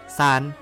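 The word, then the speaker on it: ซ าน (三): ออกเสียงว่าซาน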